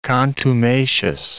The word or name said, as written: contumacious